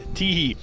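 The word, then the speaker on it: Tiéhi: So we can welcome Jean-Pierre Teehee to Scottish football, and Hamilton have confirmed that's how you pronounce it, Teehee.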